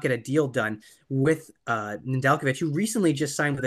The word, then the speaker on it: Nedeljkovic: Yeah, and I'd just like to point out that's the first time we've properly pronounced Nedeljkovic's name correctly.